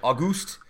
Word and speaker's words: Auguste: Film was made by Louis and August. It's August with an E at the end. Am I supposed to pronounce that differently? – Auguste Lumiere, the two French brothers who developed a camera projector called the cinematograph.